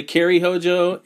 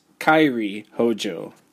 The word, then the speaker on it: Kairi Hojo: Many people say Kairi Hojo. It's Kairi Hojo.